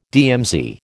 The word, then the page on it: DMZ: American English